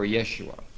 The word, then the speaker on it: Yeshua: And so we can eliminate two of these names right now by realizing that Jeshua and Yeshua are really the same name, except that Jeshua has the Latin J, which is would have been pronounced like a Y or Yeshua.